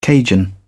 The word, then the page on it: Cajun: British English